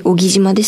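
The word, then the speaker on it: 男木島: こうした技術が、小木島、男に木の島と書いて小木島ですが、この小木島のような離島が抱える課題解決、持続可能な発展へとつながっていくでしょうかはい。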